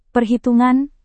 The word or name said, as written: perhitungan